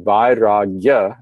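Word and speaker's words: vairāgya: You take what's called a tadhita in Sanskrit, you derive an abstract noun from that, and the first syllable takes the vriddhi, vairagya, and you add ya at the end, you get dispassion.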